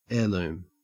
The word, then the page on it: heirloom: Australian English